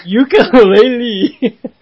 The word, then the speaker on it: Yooka-Laylee: It's actually Y-O-O-K-A hyphen L-A-Y-L-E-E. – What? – So, Yooka-Laylee.